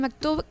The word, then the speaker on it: Maktub: se escribe M-A-K-T-U-B. Maktub.